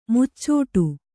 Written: ಮುಚ್ಚಟೆ

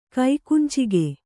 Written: ಕೈಕುಂಚಿಗೆ

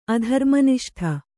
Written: ಅಧರ್ಮನಿಷ್ಠ

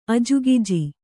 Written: ಅಜಗುಜಿ

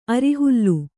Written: ಅರಿಹುಲ್ಲು

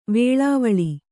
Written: ವೆಲ್ಲವಾಲೆ

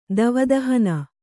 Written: ದಾವದಹನ

